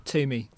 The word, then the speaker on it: Toomey: Is that the correct pronunciation of your surname? – Toomey.